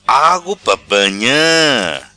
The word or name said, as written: Agu pa banha